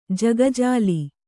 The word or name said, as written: ಜಗಜಾಲಿ